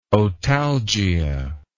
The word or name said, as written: otalgia